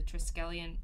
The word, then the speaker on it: Triskelion: Listening to episode 221, just wanted to say Black Panther explains the purpose of the Triskelion in the... Did I say that right? – Yeah.